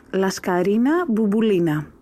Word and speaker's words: Laskarina Bouboulina: The correct way to pronounce her name is Laskarina Bouboulina.